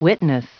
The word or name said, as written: witness